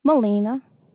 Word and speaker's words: Melina: Molina. – Molina? – Yes, ma'am. – Spell that. – It's M-E-L-I-N-A. – M-E-L-I-N-A.